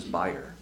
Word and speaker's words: bier: Now Gabriel laid out the bier. – B-I-E-R. – Which incidentally, the African American community pronounces buyer.